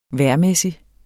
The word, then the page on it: vejrmæssig: -ˌmεˀsi; -ˌmεsi